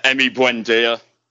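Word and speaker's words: Emi Buendía: How do you how do you pronounce it, Uh, Emi Buendia.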